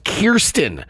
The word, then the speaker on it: Kirsten: The Spider-Man star revealed that her first name is pronounced Kirsten.